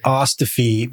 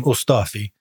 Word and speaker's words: Ostofe: We pronounce it Ostofe, but Ostofe. It's actually, as a Ukrainian last name, Ostofe. So you're not wrong.